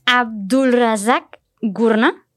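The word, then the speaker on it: Abdulrazak Gurnah: no, Abdulrazak Gurna, si lo estoy pronunciando bien, eh, es el ganador de este año, el Premio Nobel de Literatura.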